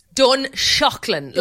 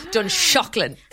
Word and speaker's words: Dunshaughlin: How do I say that? – Dunshaughlin, like as in shock to the system. – Oh, wow. – Dunshaughlin.